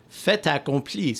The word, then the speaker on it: fait accompli: The T at the end of French words is usually silent, but if the next word starts with a vowel, A-E-I-R-U, then you pronounce it. So, fait accompli. So, accompli starts with A. So, instead of pronouncing the word fait, it becomes fait accompli.